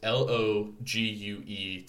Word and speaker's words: Logue: It's L O G U E town. Logue sounds like sounds like Logue to me.